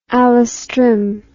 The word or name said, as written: alastrim